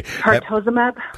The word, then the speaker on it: Pertuzumab: Pertuzumab.